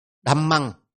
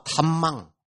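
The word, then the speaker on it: dhammaṃ: thì khó đọc này, đừng đọc đỡ hạ này, hạ, hạ, bên kia là thạ, bên này đạ, đầm măng, chứ đừng đọc đờ hầm măng, đừng đọc thầm măng, phải đọc là đầm,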